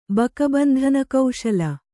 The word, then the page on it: ಬಕಬಂಧನಕೌಶಲ: baka bandhana kauśala